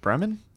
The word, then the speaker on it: Berman: it's, uh, um, uh, it's made up of uh, David Berm- Berman? Did I say that right? – Is it Berman? – Yeah, David Berman.